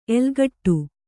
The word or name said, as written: ಎಲ್ಲೆಗೆಡು